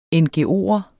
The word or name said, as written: ngo'er